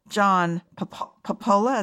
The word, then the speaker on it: Popola: And it sounds really interesting because the filmmaker, John Popola, is that how I pronounce his name? – Correct.